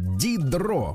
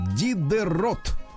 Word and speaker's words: Дидро: Так, в 1713 м родился в этот день Дени Дидро. Вообще фамилия пишется по-другому. Дидерот. Но это они половину букв не читают.